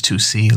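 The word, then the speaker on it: Toosii: In an interview, he said that people spell his name wrong because it's Toosi, like the number two in the C, which I find that's a clever way to say it.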